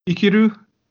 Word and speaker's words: Ikiru: Ikiru. It's spelled I-K-I-R-U.